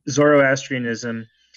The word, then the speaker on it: Zoroastrianism: Okay, yeah, you're absolutely right, and you pronounced it perfectly right. Zoroastrianism is ostensibly one of the oldest world religions.